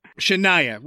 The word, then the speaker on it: Shania: Cheyenne Twain. – Shania. – Shania, whatever.